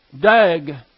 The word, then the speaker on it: dag: The Hebrew word for fish is dag, D-A-G, sometimes pronounced with a W, dog.